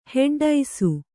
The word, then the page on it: ಹೆಡ್ಡೈಸು: heḍḍaisu